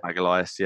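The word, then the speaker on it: Magalhães: Is it Magalhaes, I think, yeah. – Magalhaes, yeah. So he's obviously going to have signed, but he's got to go into 14-day quarantine, so he won't be available.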